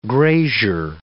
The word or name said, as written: grazier